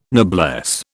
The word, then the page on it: noblesse: British English